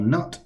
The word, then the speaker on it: Nut: And so God takes down the Egyptian goddess of the air, who's called Nut or Nut. It's spelled N-U-T.